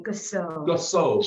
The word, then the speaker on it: Gassoh: Gasso. G-A-S-S-O-H.